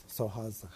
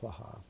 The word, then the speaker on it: Swaha: And then it ends with Soha's how Tibetans pronounce Swaha like that.